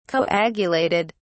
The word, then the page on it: coagulated: British English